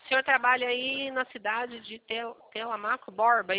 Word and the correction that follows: Telêmaco Borba: é isso? – Telêmaco Borba. – Telemaco? Eu falei errado, perdão, seu Zaqueu.